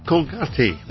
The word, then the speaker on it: Colgate: His William, as we would say in English, was Colgate, but pronounced here in Panama, Colgate.